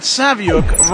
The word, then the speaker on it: Saviuk: Hello, this is Alex Saviuk for 11 O'Clock Comics, and that Saviuk rhymes with book.